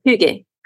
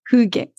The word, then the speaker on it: Hygge: And I know I've seen you have written a little bit here and there about this concept of Hygge. Is that how I pronounce it? So go ahead, correct me. – It's Hygge. – Hygge. – Very good.